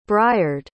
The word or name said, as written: briard